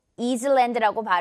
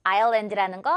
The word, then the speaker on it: island: 이건 유명한 예시가 될것 같은데, 섬이라는 단어인데, 이거 이즐랜드라고 발음하는 사람 아무도 없긴 하죠. 이거는 다들 아일랜드라는 거잘 알고 있어요. 아일랜드 이렇게 발음이 돼요. S가 무음입니다. S는 발음되지 않아요.